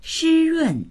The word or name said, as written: shi1run4